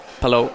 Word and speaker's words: Palou: Is it Palou or Palou? – Palou. – It is Palou. – It is Palou. Yeah. Take that, Townsend.